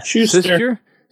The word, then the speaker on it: Schuster: Next thing I want to cover would be let's just do the Simon and Suture. How do you say that? Schuster. Schuster, thank you.